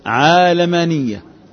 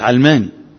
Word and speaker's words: عالماني: على القائلين بالقياس عالمانية، عالمانية. والمفرد فيها يسمى عالماني مش علماني، عالماني تنطق هكذا.